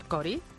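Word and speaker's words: Chory: O sea, ¿cómo se llama Joan Chori? – Joan Cori. – Cori. – Cori, Chori no. – ¿Por qué? – El Chori no tiene la CH, no se pronuncia en inglés.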